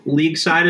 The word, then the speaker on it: LeagueSide: So LeagueSide is a tricky word. It's spelled like sports league, L-E-A-G-U-E, side, S-I-D-E.